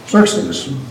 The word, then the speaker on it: Xerxes: The last of them, Xerxes, that's who I call him. How do you pronounce that? – Yeah. – Xerxes.